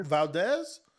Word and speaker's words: Valdez: I'm saying his name right? Vadquez, how you say his name? – Valdez? – Valdez, yeah. I hope Valdez get upset tonight since he won't cheat with that magic potion and stuff like that.